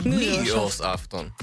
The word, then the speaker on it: nyårsafton: Nyårsafton. Nyårsafton. Betoning på ny. Det kommer Nej, nytt. Det, det, ja, men det är betoning på det afton.